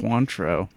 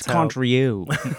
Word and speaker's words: Cointreau: Cointreau. That's not how you say it. That's Cointreau.